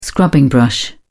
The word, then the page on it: scrubbing brush: British English